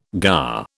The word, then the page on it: gar: British English